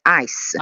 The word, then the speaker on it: ICE: Mi permetto soltanto di correggere la pronuncia perché si pronuncia come ghiaccio, cioè ice, ICE senza la I finale, ok.